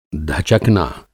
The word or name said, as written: धचकना